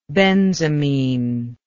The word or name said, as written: benzamine